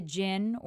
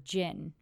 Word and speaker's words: djinn: Seems like there are many concepts associated with the word djinn, which is also the name of this band. It starts with a D, D-J-I-N-N, the D is silent.